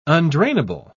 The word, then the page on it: undrainable: British English